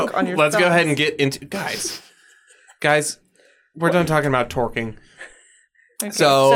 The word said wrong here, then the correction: twerking: It's pronounced twerking.